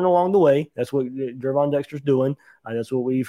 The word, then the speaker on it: Gervon: So Gervon Dexter, got to say his name right, not Gervin, Gervon.